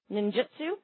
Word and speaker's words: ninjutsu: Not only is a traditionalist who practices classical Japanese ninjutsu, did I pronounce that correctly? – Ninjutsu.